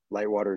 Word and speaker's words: Litewater: Lightwater is spelled LITEWATER.